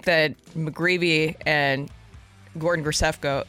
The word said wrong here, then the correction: Graceffo: Grisefko I can't even say that right. Graceffo.